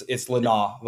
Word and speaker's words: Lynaugh: And it's Lynaugh, by the way. – Lynaugh? Okay, yeah, sorry. I'll mess it up. – It's a very challenging name to pronounce. There's about four different ways. And then depending on what part of my family you're in, Lynaugh, you have a different pronunciation. It's an internal debate.